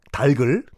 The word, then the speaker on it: 닭을: 후라이드 치킨이 여러분 백인들은요 닭 달글 닭을이 아니라 달글이 정확한 발음이죠. 달글 튀겨 먹지 않아요 원래 로스팅이라고 해서 오븐에 구워 먹죠.